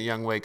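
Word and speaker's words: Younghoe: Young Ho Koo, baby. – I thought it was Young Wei. – It is 100% Young Wei Koo, but.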